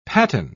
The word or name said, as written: patten